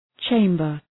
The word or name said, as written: chamber